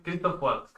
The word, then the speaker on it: Christoph Waltz: Eh, la primera, el primer personaje, el primer papel de Christopher Waltz, si lo he dicho bien. Que... Christoph Waltz.